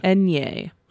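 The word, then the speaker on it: eñe: Do you know how that is pronounced as like a consonant in Spanish? – No, I do not. – Enye.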